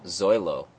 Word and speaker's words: Zoilo: Oh, how do you pronounce his first name? I'm just calling him Zoilo. – Zoilo. – Zoilo. Am I still pronouncing that wrong? – No, I think it's no, you got it.